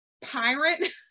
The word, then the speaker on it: PYRT: It is called, and it's PYRT, pronounced pirate.